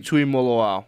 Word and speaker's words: Tuimoloau: If we, without an all-time performance from JT Tuimotalolo, or however you pronounce it. – JT Tuimoloau.